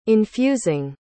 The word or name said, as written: infusing